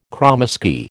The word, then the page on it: kromesky: American English